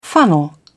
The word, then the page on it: funnel: British English